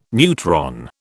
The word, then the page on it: neutron: British English